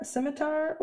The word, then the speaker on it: scimitar: And then I choose scimitar or did I say that right? – Yes.